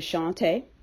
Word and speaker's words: Shantae: My first name is Shantae, the way it's spelled. Some people just do not pronounce it correctly.